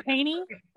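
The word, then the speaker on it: Koehne: So in July of 1982 in Waco, Texas, my hometown, there were two fishermen that were heading out to a place called Caney Park. It's spelled weird. It's K-O-E-H-N-E. So Caney? Like Koenig, like Sarah Koenig, who does cereal? – Mm-hmm.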